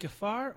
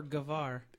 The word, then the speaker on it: Gafar: Is it Gafar or Gavar? – It's pronounced either way. It's G-A-F-A-R. – Oh, okay. – But F is pronounced V very frequently in this area. You'd sound more intelligent if you pronounced it as a V, but everyone would understand you if you pronounced it as an F.